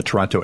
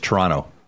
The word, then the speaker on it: Toronto: We have Mark Zucchino, who I believe is in his home of Toronto, in the Toronto area, which, by the way, you don't pronounce that second T, Frank, if you're from Canada. It's Toronto. There's no second T. You don't say Toronto.